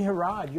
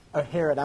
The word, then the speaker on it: Herod: Well, Leslie Herod, you're absolutely right. Herod, I'm sorry. That's my New Orleans pronunciation.